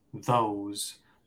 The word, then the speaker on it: those: Ahí tenemos la O, espacio E, se pronunciaría O, ¿no? Igual que la letra O. O space E sounds like O. O's, those. Entonces no, no, no se pronuncia la E. Simplemente nos ayuda a saber cómo pronunciar esa O space E. – Perfect, great, genial. Así que esa última E no la pronunciamos, pero cambió la pronunciación de la O, ¿no? Entonces queda those.